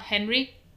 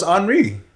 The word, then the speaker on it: Henri: I'm sorry, did you just French pronounce the hurricane? It's not Henry? – No, it's Henri.